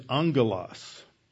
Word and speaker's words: angelos: And the Greek word for messenger is angelos. When you have a double G in Greek, it's pronounced NG.